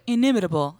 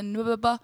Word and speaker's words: inimitable: And it's funny because like my really good friends who support me, she's like, yeah, girl, we were listening to your podcast, you know, the nubaba. I said, what? Nubaba? I was like, that's not how you say it.